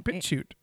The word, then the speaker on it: BitChute: BitChute. – Okay, BitChute as well. So look for Scotty White Company to find us there. – So we're on BitChute because it's a... – What is BitChute? – It is a YouTube alternative.